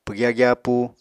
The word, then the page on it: pwgya gyapúu: Apatani